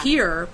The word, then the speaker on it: hir: I am, and that's why it's called H-I-R yeah. – here, pronounced, but it's a mix between her and sir, right?